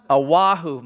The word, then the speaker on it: Oahu: Oahu, my goodness, Oahu last week for the week,